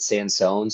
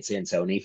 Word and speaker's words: Sansoni: He actually said on the internet that he pronounces it Sansone so that native English speakers don't butcher it, but I'm now saying it Sansoni from now on So anyway, he gave me a lesson on the appropriate distance for which to hold my Italian gesturing hand.